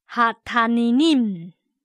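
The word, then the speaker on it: 哈 塔 尼 尼 姆 (התנינים): 今天我们要看的字是大鱼，如果把它前面的定冠词合起来是这大鱼，读音是哈塔尼尼姆，哈塔尼尼姆。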